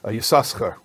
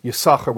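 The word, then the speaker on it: Yisachar (יששכר): he pronounced it Yisachar, with two sins. So the Rebbe actually said, we pronounce it always as Yisachar with one sin, and the Rebbe said, I learned this from my father.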